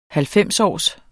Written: halvfemsårs

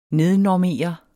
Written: nednormere

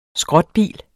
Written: skrotbil

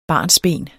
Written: barnsben

